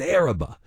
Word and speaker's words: Arriba: It looks like Arriba, but locals pronounce it Araba.